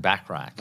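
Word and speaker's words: Bachrach: How do you say that? Bark? – I'm saying Backrack. – Backrack. – Like Bert Backrack.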